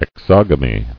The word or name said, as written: Exogamous